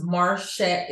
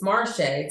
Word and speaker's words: Marché: hit me up at Facebook at Marche, Gingru, M-A-R-C-H-E, with an accent at the top of the E. It's French. Last name is spelled G-E-N-D-R-E-W. My IG is Marshay. It's Marshay. So it's M-A-R_S-H-A-Y for folks that don't know how to pronounce my name when they see it. And then my LinkedIn is just my name, Marshay Gingru, M-A-S-W.